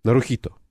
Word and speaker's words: Нарухито: Куда правильно ставить ударение? Нарухито. – Ну, вы нормально поставили ударение.